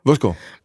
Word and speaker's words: Vodskov: Altså, vi har altid sagt Vodskov. – Vodskov. Men det er jo så... Altså, så burde det jo staves V-O-S-K-O-V. – ja, Men det er sådan, det udtales.